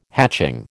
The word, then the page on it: hatching: American English